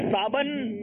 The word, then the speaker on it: صابن: آئیے دیکھتے ہیں کہ کون سا تلفظ درست کرنا ہے. بتائیے خانپور والوں نے کیا درست کرنا ہے. صابن یا صابن؟ – صابن.